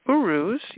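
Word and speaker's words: Uruz: Then the third one I have is called Urus. I hope I pronounced that one correctly. And Urus, yep, looks like I did, represents the wild aurochs, and it symbolizes health, vitality, physical stamina, resistance to illness or negative influence.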